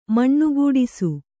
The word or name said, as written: ಮಣ್ಣುಗೂಡಿಸು